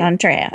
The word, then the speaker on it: D'Andrea: Our next guest is the amazing Paula D'Andrea. And did I pronounce that correctly? Andrea? D'Andrea? – D'Andrea. Thank you. – D'Andrea.